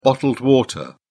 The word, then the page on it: bottled water: British English